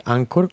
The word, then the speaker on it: Anchor: Que Anchor se escribe A-N-C-H-O-R eh, Ancor, Ancla.